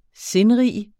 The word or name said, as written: sindrig